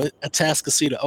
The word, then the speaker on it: Atascocita: So I'm like, what in the world is Atascocita? Like, where is this? But no, Atascocita. Okay. All right. Excuse my East Texan. That's how, I pronounce it that way. But now I know it's Okay.